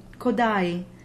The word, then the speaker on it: Kodály: When they start a syllable, they do form that J glide, and when they end the syllable, they create the diphthong, just as in the composer's name, Kodai.